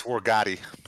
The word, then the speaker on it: Wargotti: Sorry, Wargoat. I always say his name. Is it Wargoat? Yeah. I think it's Wargoat. – Wargoat. – It's Italian. It's Wargotti. – Wargotti.